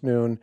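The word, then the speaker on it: noon (ن): In this particular case, it's the 14th letter of the Arabic alphabet. It stands for noon, or is pronounced noon, and stands for Nazarene, term of derision by Muslims, a term of solidarity by those who wear the pin.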